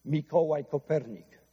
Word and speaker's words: Mikołaj Kopernik: Mikołaj Kopernik, that's how it's properly pronounced in the native language.